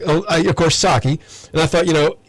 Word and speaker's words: Psaki: And I was thinking, I was trying to get my arms around how you pronounce her name, and it just doesn't sound like a name. It sounds more like a word. It's Jen Psaki, and I, of course, I love Psaki.